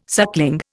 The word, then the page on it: suckling: Indian